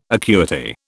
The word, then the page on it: acuity: British English